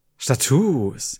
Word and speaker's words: Status: Es ist aber Status. Es wird genauso geschrieben, aber mit langem U ausgesprochen. – Status.